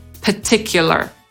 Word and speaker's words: particular: Można sobie wymowę tego słowa jednak po amerykańsku nawet ułatwić, bo niektórzy Amerykanie tego pierwszego R nie wymawiają. Czyli tak jakby początek tego słowa mówią po brytyjsku, ale kończą już na swoje amerykańskie R. I wtedy mówią particular.